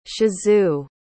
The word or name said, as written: shizu